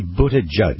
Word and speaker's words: Buttigieg: I can't even really pronounce his last name. Buttigieg. – It's actually Buttigieg.